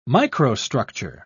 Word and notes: microstructure: British English

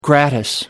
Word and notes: gratis: American English

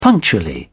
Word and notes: punctually: British English

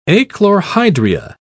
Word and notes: achlorhydria: British English

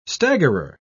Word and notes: staggerer: British English

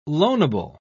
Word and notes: loanable: British English

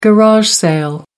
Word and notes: garage sale: American English

